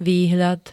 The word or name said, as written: výhľad